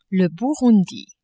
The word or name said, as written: Burundi